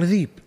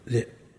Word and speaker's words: ذئب: عندنا طالب من بيشة قبل 15 سنة أو أقل عندنا، في نقول له ابن أبي ذئب، عجز ينطق، يقول ذيب، يقول له شلون ذيب، ذئب، أخذ لسانه راح الذيب جاء الذيب، والله عجز عنه ينطق ذئب، إحنا سهلة يعني، وشاب ودارس مع الناس يعني في الجامعة، وما طاوعه لسانه عليها.